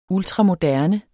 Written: ultramoderne

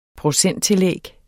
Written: procenttillæg

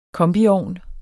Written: kombiovn

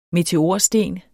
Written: meteorsten

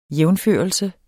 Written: jævnførelse

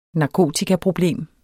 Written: narkotikaproblem

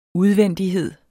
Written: udvendighed

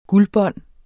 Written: guldbånd

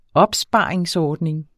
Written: opsparingsordning

